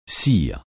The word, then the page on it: cire: francais